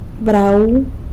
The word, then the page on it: brau: sanlucasquiavini